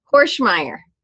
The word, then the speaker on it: Hoernschemeyer: I'm joined by my friend, Devon Hoernschemeyer.